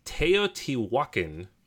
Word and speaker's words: Teotihuacan: Alrighty, so I had to write this one out phonetically because I knew I was going to say it wrong. It's Teotihuacan, City of Gods.